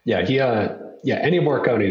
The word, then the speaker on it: Morricone: Ennio Morricone, am I saying that correctly? – It's Morricone. – Morricone, sorry.